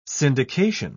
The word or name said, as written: syndication